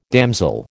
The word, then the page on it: damsel: American English